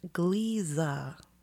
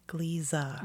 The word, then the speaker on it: Gliese: It's pronounced Gliese. Gliese.